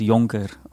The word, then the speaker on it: Jonker: The, the, it's actually like you pronounce it like a Y, so it's Jonker.